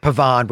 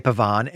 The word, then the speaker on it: Pavane: I'm going to pronounce it Pavane, which is, I think, what you would in French.